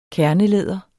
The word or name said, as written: kernelæder